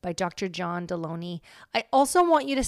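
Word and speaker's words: Delony: I am sitting here with the one and only Dr. John Deloney, rhymes with baloney.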